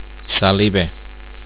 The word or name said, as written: saliba